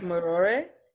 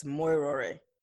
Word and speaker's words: Morare: It's pronounced Morare. – Morare. – Yeah, so just roll the U's. The U's almost sound like O, so it's Morare.